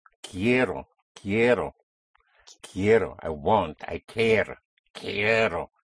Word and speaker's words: quiero: Spelled Q-U-I-E-R-O. The Q-U has the sound of a K. So, quiero, quiero, it's I want. Again, the stress, whenever you have two syllables, and the second syllable starts ends in a vowel, the syllable before last is the first one. This is when you put the stress.